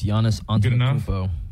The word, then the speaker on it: Giannis Antetokounmpo: You want to judge my pronunciation there? – I mean, yeah, I think that's pretty close on. I think it's Giannis Antetokounmpo.